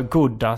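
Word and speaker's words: gouda: Jag läste på om man kunde ha edamer eller chauda. Eller goda, som man också kan uttala det.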